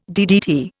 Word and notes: DDT: Indian

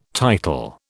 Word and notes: title: British English

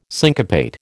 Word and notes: syncopate: American English

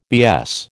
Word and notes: BS: American English

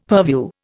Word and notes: purview: Indian

